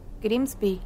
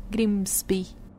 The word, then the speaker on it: Grimsby: Sí, pues se escribe medio raro. Grimsy. Es G-R-I-M-S-B larga. – I. Grimsby. Grimsby. Bueno,